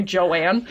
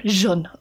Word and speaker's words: Jeanne: And Jeanne, gosh, it's pronounced like, it looks like Joanne. So I keep wanting to say Joanne. – Yeah. – Jeanne.